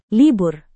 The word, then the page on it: libur: indonesian